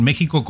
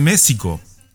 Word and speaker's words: México: Pero yo siempre me he preguntado por qué eh, pronuncian México con J cuando es con X y ahí no me han sabido responder algunos de ustedes.